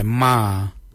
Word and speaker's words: Marr: Kujo, G-O-O-D-J-A-L. And then again, G-O-O-D-J-A-L. Kujo, kujo, 4-4. For 5, we say ma. Ma is M-A-R-R. Ma for 5.